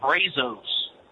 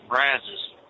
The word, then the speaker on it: Brazos: You were talking about Brazos County. That's actually pronounced Brazos. – Brazos? – Brazos, yeah.